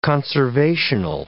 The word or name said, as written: conservational